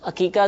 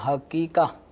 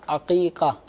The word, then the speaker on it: عقیقہ: سب سے پہلے تو ان کا تلفظ درست ہونا چاہیے، یہ حقیقہ. – جی جی، یہ بھی لوگ اصل لفظ حقیقہ نہیں ہے، یہ عین پھر قوف پھر یا پھر قوف دو نقطے والا اور اس کے بعد ہا، عقیقہ عین کے ساتھ ہے.